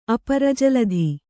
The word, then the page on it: ಅಪರಜಲಧಿ: Kannada